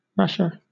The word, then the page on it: rasher: British English